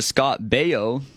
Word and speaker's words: Scott Baio: Happy 61st birthday to Scott Baio.